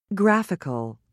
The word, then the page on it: graphical: British English